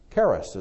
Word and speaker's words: charis: Charis is the way they pronounce it.